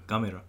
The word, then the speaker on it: Gamera: Gamera.